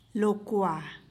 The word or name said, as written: loo kwa